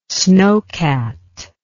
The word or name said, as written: snocat